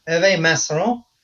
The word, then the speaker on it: Herve Manceron: So our special guest this week is Herve Manceron.